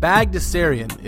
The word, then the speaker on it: Bagdasarian: Bagdasarian is actually a better way to pronounce that.